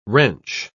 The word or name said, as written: wrench